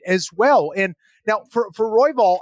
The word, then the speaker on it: Royval: And the way I see it, it's basically like the fundamentals, the consistency of Moreno versus the chaos of Royval. So, yeah, did I say that right? Royval? Yep. Okay.